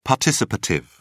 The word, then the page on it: participative: British English